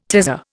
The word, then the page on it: tazza: Indian